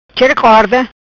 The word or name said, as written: Chera Corda